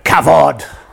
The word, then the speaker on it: kavod: But the word there for honour and honouring is this wonderful word, kavod. So say it with me. You have to say it like a big rabbi with a huge beard and a big hat.